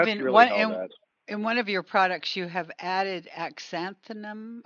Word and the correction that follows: astaxanthin: I'm not sure if I said it right. – Astaxanthin? – Yeah.